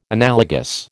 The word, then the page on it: analogous: American English